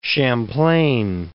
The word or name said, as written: Champlain